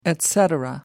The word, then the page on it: etc: American English